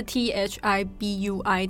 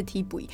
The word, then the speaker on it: Thi Bui: Thi Bui 其实它的呃拼音就是以美国的拼音来说是 T H I B U I，但是它的发音是 Thi Bui。